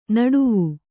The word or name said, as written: ನಡುವು